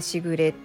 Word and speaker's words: 時雨: 小雨とも呼んでその雨がパラパラと降る様を表していますというふうに、まあ、あの言われているんですけれどもあのしぐれって聞いたら「時雨」って書いて「しぐれ」って読みませんまたそれ